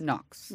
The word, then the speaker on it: NOx: I don't feel like I'm not saying that right because it's for nitrogen oxide, hard NOx. – NOx. – NOx. – I think, yeah. I mean, how else would you say NOx? – N-O-X.